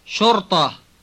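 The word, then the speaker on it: Shorta (شرطة): Shorta. – Notice that it has the same hollow-sounding heavy ta as does the word mahatta. Listen to it. – Shorta.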